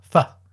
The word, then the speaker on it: pho: They've got a design of a bowl of noodles or faux, or pho, I suppose it's pronounced. And it says, faux real. Yes. No, isn't it right that the correct pronunciation of this is pho? – Oh, I don't know. – P-H-O.